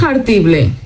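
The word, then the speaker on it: jartible: Eso de pronunciar tanto la J ahí de hartible es una H aspirada, ¿eh? Hartible.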